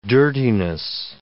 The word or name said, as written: dirtiness